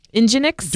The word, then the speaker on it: NGINX: Nginx? Don't make me call up Alan. But it is pronounced Nginx.